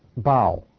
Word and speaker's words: bough: He says about Joseph, he said, Joseph is a fruitful bough. Did I say that right? Bough? Okay, good, good.